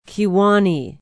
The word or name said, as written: Kewaunee